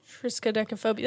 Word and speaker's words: triskaidekaphobia: So the fear of number 13 is called, I'm going to butcher this, triskaidekaphobia. – Triskaidekaphobia.